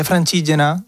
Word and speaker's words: Francigena: La vía Francigena, que se dice así en italiano, yo pensaba que era una vía que tenía que ver con San Francesco, ¿no?